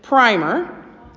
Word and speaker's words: primer: If you are talking about a book that someone is using to learn from, like an early reading book, that is actually pronounced primer.